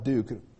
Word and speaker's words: Duc: But I remember one guy, his name was Bud Duke. Now, that's how you pronounced it, but he spelled it D-U-C.